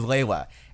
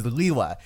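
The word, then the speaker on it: Lugia: In Generation 1, officially the thing. Yeah. Greg pronounced it Liwa, but in the official pronunciation guides, it is marked down as Lewa.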